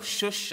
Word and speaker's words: Shush: We're in the Persian capital city of Susa, or Shush, as it's pronounced today.